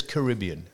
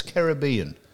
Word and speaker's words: Caribbean: Oh, and everyone says Caribbean. Now, it's Caribbean. The Caribbean islands and Monaco. They're the pronunciations that are correct.